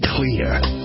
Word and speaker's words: Xlear: Introducing Clear. It's pronounced clear, but it's spelled X-L-E-A-R.